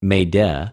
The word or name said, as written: μηδε